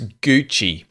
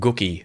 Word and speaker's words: Gucci: Love how it looks like it's spelled Gookie.